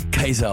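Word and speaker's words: Caesar: Und für alle Oberklugscheißer, die wahrscheinlich eh schon einige dann erscharen werden, weil jetzt da an Cäsar gesagt habe, ja, theoretisch würde es eigentlich Kaiser ausgesprochen werden, was dann der Kaiser geworden ist.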